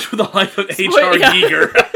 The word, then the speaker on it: Giger: Giger. – I think it's pronounced. No, it is Giger.